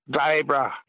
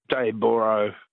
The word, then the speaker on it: Dayboro: It's spelled O-R-O through me. – It is. – Yeah. It should be Daybra, B-R-A-H. – Yeah. Everyone south of the border calls it Dayboro, but yeah, fair enough. – Yeah. Daybra.